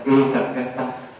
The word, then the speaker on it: Ekaggata: Chữ này đọc là Ekaggata.